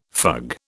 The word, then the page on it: fug: British English